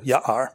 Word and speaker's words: Ja'ar: Ja'ar means forest in Hebrew, and the J is not pronounced in our English J, it's a Y, so Ja'ar.